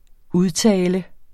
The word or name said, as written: udtale